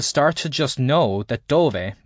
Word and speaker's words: dove: The first part, dove, pronounced dove, sounds a little like the word duvet.